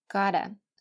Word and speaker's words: gotta: 这里要注意最后一句话的发音 I gotta go，其实呢上边所有的 got to 都应该像最后一句一样发成 gotta，所以我们再来看一下这四句的发音 I have gotta go, I've gotta go, I gotta go, I gotta go。